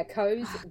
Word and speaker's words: Koos: Did you say his name's Coes? – Yeah, Coes. – Cozy. – There you go. – Yeah, it's spelt K-O-O-S, but rhymes with toes when you pronounce it.